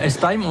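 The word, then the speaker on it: S'time: Alors ça s'écrit S apostrophe. – Oui. – T-I-M-E, comme Estime, on aurait tendance à penser à de l'anglais, mais en fait, c'est un jeu de mots sur le mot estime.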